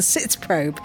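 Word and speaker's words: Sitzprobe: So I'm joining Chris and Simon on a very exciting part of the process, which is called the Zitz Probe.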